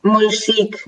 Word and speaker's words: Monchique: Monchique, Monchique.